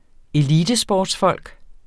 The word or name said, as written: elitesportsfolk